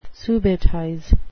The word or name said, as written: subitize